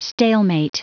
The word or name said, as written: stalemate